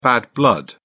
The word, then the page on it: bad blood: British English